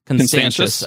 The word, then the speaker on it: Constantius: Now, Constantius, am I pronouncing Constantius? – Constantius.